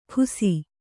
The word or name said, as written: ಖುಸಿ